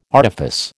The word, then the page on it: artifice: American English